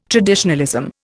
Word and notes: traditionalism: Indian

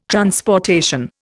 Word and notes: transportation: Indian

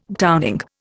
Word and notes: browning: Indian

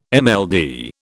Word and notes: MLD: British English

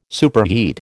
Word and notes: superheat: American English

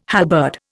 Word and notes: halberd: Indian

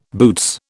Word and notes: boots: British English

